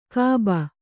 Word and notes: ಖೂಬಿ: Kannada

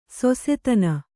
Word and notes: ಶಶಾದನ: śaśadana

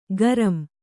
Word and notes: ಗರಂ: garam

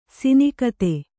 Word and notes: ಸಿನಿಕತೆ: Kannada

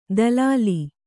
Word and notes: ದಲಾಲಿ: dalāli

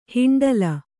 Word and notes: ಹಿಂಡ್ಲಿ: hiṇḍli

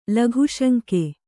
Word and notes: ಲಘುಶಂಕೆ: Kannada

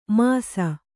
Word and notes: ಮಾಸಿ: māsi

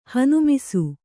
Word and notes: ಹನುಮಿಸು: Kannada

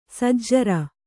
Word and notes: ಸಜ್ಜರ: sajjara

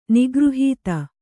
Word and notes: ನಿಗೃಹೀತ: kannada

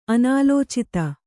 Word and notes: ಅನಾಲೋಚಿತ: Kannada

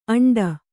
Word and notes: ಆಂಡ: Kannada

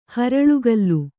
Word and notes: ಹರಳುಕಲ್ಲು: kannada

